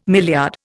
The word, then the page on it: milliard: Indian